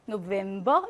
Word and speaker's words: November: 근데 외래어에서는요, V로 발음합니다. Clavier, November 이렇게요.